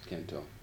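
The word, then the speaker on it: Cần Thơ: You were teaching English and in Canto, if I pronounce it correctly. – Canto. – Yeah, or at least close enough. And that should be enough of the intro for the first part of the transcription later.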